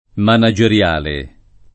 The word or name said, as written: manageriale